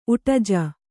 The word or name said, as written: ಉಟಜ